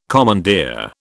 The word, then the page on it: commandeer: British English